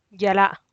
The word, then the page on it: yag la’a: Teotitlán del Valle Zapotec